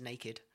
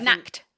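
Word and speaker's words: Nacht: For a long time, I just pronounced the word for night as naked. Mm-hmm. Yeah. But I Nacked.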